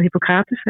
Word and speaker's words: Hippocrates: dat je kon eten of de eet van Hippocrates. Hè? Zeg ik dat goed? Ja.